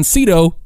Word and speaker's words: Sydow: And Max von Sydow, it rhymes with Greedo, is playing a helpful old cyborg guy that some people are calling the Vicar.